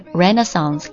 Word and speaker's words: Renaissance: 第一个是来自于歌名 Renaissance，它有两种读音啊，美式的是 Renaissance，歌曲里面的这个歌手他也是发的这个 Renaissance 的音，那它还有一个英式的读法叫 Renaissance，记一个就好了。